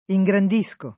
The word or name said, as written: ingrandire